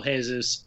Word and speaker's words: Jesus: I was involved in a trade in fantasy and was like, changed out Morata for Gabriel Jesus. Who, God, come on. Jesus, is it the hard J, Pauly? Is it the hard J? – Hard J, Brazilian, it's hard J.